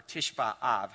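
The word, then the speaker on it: Tishba Av (תשעה באב): And in fact, you can Google that, just even the ninth of Av, or Tishba Av is how it's said in Hebrew.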